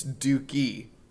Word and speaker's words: Doki: It's Doki. His name is Doki. Or Doki. Doki. – I think it's Doki. – It's Doke? – Doki. So, like, Do like Homer Simpson and Key like Loki.